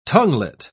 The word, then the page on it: tonguelet: British English